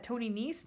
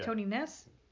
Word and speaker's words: Nese: Okay, and is that Tony Nese? Yeah. Tony Ness? – You had it the first time. Quit while you're ahead, champ.